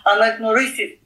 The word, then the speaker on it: Anagnorisis: Burada e, ilk önce bir kavramla umarım doğru telaffuz edebileceğim. Biraz şeyden önce çalıştık ama programdan önce. Anagnorisis kavramıyla. – Evet harika e, söyledin.